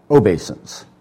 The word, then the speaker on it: obeisance: I looked it up. It's pronounced obeisance.